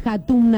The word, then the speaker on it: Hatum: ¿Cómo se escribe Hatum, Andrés? Así no está en Instagram, lo quiero seguir. – Dice, H-A-T-U-M. – H, con H en vez de J y con M al final.